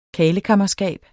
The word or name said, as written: karlekammerskab